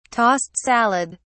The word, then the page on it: tossed salad: British English